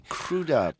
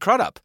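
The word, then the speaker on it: Krüdopp: Crude up. – But there's a whole subsection of crude ups in the States who pronounce it crud up.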